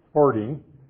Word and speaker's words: farthing: Because he says, what is a farthing worth parted in 12? Now, the word farthing, pronounced in Middle English, farting, is 1/4 of a penny.